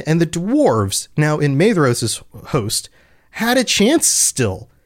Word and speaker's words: Maedhros: And I just realized, I've been doing it again. Pronunciation is rough. Maedhros, it's a DH. I talked about this on the last episode, and yet I forgot.